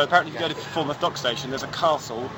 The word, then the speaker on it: Falmouth: For example, today we're going to go to Falmouth or Falmouth? – Falmouth.